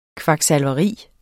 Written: KVAKSALVERI

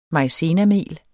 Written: maizenamel

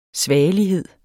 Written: svagelighed

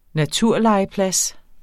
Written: naturlegeplads

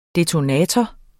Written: detonator

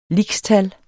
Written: lixtal

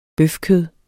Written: bøfkød

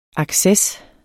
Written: ACCESSERNE

